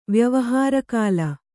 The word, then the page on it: ವ್ಯವಹಾರಕಾಲ: vyavahāra kāla